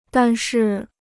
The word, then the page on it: 但是: dàn shì